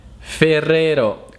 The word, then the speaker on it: Ferrero: Ferrero, qui sono le R un po' difficili, Ferrero, e dovrete allenare.